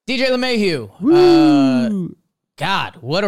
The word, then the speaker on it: LeMahieu: Baseball Reference has his pronunciation as LeMahieu. Hugh. It's H-Y-O-O. Hugh. Hugh. That's a weird way. H-Y-O-O.